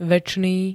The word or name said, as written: večný